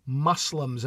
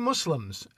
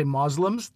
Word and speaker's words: Muslims: Also, you know what may confuse you is the fact that Asif says Muslims as though he's pronouncing the word musk. And I say Muslims. Other people say Muslims. That's really not done anymore. But feel free to go Asif's way if you like. But really, Muslims is just fine.